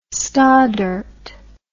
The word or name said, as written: stoddert